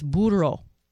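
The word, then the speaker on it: Burro: You say it like this, Burrow. Roll your R's, Burrow.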